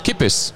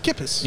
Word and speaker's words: Kippis: Kippis. – Kippis. – Yeah, you can remember, like, keep pissed.